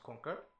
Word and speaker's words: KNQR: So the brand is spelled K KNQR. To those that didn't know, it's pronounced Conquer. You're welcome.